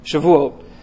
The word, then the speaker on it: Shavuot: So it's actually traditionally overlaps with the Jewish holiday of Shavuot. I worked on my pronunciation this week. Shavuot, which was the beginning of the wheat harvest.